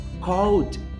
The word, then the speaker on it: called: D de dado, como eu falei agora, D de Danilo, é, portanto, o passado de call é called.